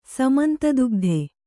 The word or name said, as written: ಸಮಂತದುಗ್ಧೆ